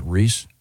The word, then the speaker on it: Rhys: He pronounces it Rhys? – Rhys. – Does he? I don't know. – Oh, it is Rhys.